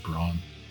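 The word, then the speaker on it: Brann: I guess they can pronounce it Braun. We don't spell Bran.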